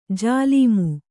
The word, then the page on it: ಜಲ್ಮ: jalma; Kannada